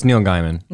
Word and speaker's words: Neil Gaiman: Oh, it's Neil Gaiman.